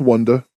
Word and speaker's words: WondaGurl: We get Wanda Girl, or is it Wanda Girl? I think it's Wanda Girl. Wait, Wanda. No, WONDAGURL. – No, that's Wanda. Like Wonder.